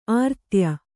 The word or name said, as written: ಆರಾಧ್ಯ